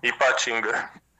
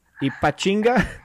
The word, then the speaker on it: Ipatinga: My town is named Ipatinga. I don't know if you can pronounce it. – Ipachi? – Ipatinga. – Ipatinga? – That's it.